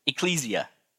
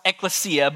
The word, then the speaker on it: ekklesia: Now, how many of you have pronounced it ecclesia? I've heard it a few times, right? So it's traditionally pronounced ekklesia, but it can be pronounced ecclesia, or it can be pronounced ekklesia.